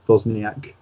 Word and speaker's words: Wozniak: Wozniak Art. That's N-W-O-Z-N-I-A-K Art, which is A-R-T. Or on Instagram at Nathan Wozniak, but the same way.